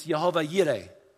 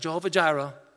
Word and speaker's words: Jehovah Jireh: Then another one, which you probably has become more popular lately because of a song, Jehovah Jireh, it's Jehovah Yireh. Not Jehovah Jireh, but Jehovah Yireh. That's the pronunciation for how it's to be said.